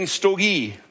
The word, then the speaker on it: storge: And then storge, that's how you pronounce it.